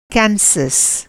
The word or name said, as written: केन्सास